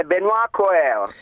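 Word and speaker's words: Benoît Cœuré: È Benoît Coeur. – Lui la pronuncia ce l'ha molto migliore della mia.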